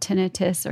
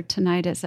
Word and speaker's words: tinnitus: like they call it tinnitus or tinnitus. I've heard it pronounced both ways.